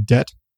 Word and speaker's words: debt: It's like people who pronounce the B in debt.